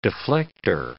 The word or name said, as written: deflector